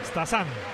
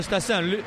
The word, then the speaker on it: Stassin: Stassin, es como se pronuncia. Es una pronunciación... – Stassin, Stassin.